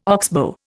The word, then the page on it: oxbow: Indian